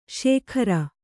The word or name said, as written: ಸಗ್ಗಾರ